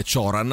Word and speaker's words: Cioran: Allora, senti un po', lo sai che è uscito, è riuscito per Adelphi in questi giorni un libro di Emil Cioran, o Cioran, che dir si voglia, che lui era uno scrittore rumeno, quindi se la pronunciamo la rumena è Cioran, altrimenti siccome lui è andato...